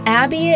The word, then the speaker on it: Abi: Again, Abby is spelled A-B-I. I don't know if I've even said that before, so A-B-I is how you spell my name,